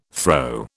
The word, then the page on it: throe: British English